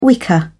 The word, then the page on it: wicker: British English